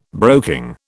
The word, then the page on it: broking: British English